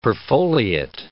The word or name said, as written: perfoliate